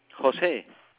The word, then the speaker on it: Jose: Jose. J-O-S-E. – Okay. J-O-S-E.